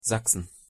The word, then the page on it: sachsen: German